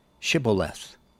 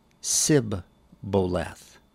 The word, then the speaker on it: Shibboleth: If he said, 'No,' then they would say to him, 'Then say Shibboleth.' And he would say Sibboleth, for he could not pronounce it right.